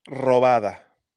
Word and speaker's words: robada: Robada. – Robada. You have to roll your R's. – You got to roll that R.